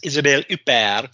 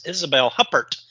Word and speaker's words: Isabelle Huppert: My next is Isabelle Huppert, or as Google says, the American pronunciation is Isabelle Huppert.